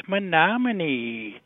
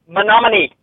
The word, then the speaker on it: Menominee: Congrats on the new couple cast and the pronunciation of the Wisconsin place is Menominee.